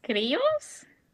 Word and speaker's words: criollos: Yeah, what I'm seeing is it's something called a Creolo. A Creolo? I'm sorry, I can't pronounce this, but... – It has a double L, so I'm assuming it's criollos.